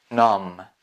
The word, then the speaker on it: numb: Pay attention, we are not pronouncing B, numb, numb, peril, peril.